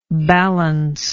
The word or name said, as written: ballance